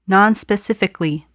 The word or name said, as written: nonspecifically